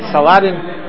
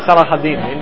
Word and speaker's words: Saladin: I don't know how you pronounce it in English, Saladin. In Hebrew it's Salahaddin, in Arabic. Saladin.